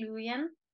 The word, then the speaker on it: Louien: Yeah, you can say it however you like. I say Louien.